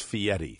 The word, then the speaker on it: Fieri: Now, his name is spelled F-I-E-R-I, but it's pronounced Fieri.